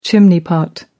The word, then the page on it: chimney pot: American English